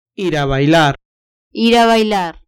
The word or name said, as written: ir a bailar